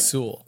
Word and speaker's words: Sewell: It's Sewell. It's just like Sewell. – Sewell, yeah. Just kind of flow through all the letters and it just becomes one mush.